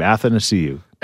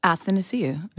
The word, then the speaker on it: Athanasiou: You overthink his name. Athanasiou. – Athanasiou. – Athanasiou. – Athanasiou. – Athanasiou. – Okay, I'm missing a syllable completely. – Yes, say it. – Athanasiou, yeah. Which is what I've been saying, but you kept saying Anne, and I said it's spelled A-T-H, so I was correct, and you tried to suck me down into the vortex of wrong pronunciation with you.